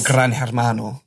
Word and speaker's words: Gran Hermano: Yeah, but not Gran Hermano, the Spanish equivalent. – That's good pronunciation.